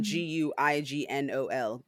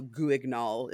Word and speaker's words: Guignol: it's written Grand, and then G U I G N O L. So Guignol, if you are have a coarse American pronunciation, but I'm looking online and it's Grand Guignol.